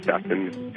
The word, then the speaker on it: Stephen: My name is Stefan, and I'm coincidentally African-American. So people automatically assume that my name is Stefan when they're reading it, even though it's spelled S-T-E-P-H-E-N.